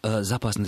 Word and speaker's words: запасный: Запасный, запасной, то и другое до сих пор можно. Старшая норма – это запасный. Запасной – это более, скажем так, новое. Это связано с конкуренцией ударения в прилагательных, которые, в свою очередь, связаны с различием книжного, церковно-славянского и разговорного древнерусского языка.